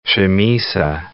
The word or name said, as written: chamisa